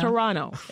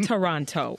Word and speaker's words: Toronto: Yeah, because we don't say the second T, right? Toronto. It's Toronto. It's not Toronto. – Okay. – As you Americans say.